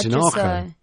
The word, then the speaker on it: Tinajo: But no, I'd call that Tinajo. You see. – It's a long A.